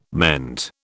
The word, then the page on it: meant: British English